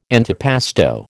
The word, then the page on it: antipasto: American English